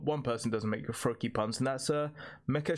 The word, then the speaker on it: Froakie: Yeah, Froakie, was like rhyming with low key for me.